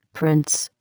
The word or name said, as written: prints